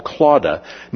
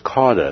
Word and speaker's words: Clauda: Now, Clauda, sometimes you will see it also spelled as Cauda. And the reason for the difference is because even during Dr. Luke's day, it was spelled and pronounced both ways.